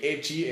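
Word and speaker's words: ecchi: Eh, Echi es la pronunciación de la palabra H, la primera palabra del hentai.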